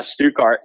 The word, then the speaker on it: Stuttgart: You went to Italy during the off season to race the, help me pronounce this. Is this Stuttgart SX? – Yes. Okay. Yes, Stuttgart.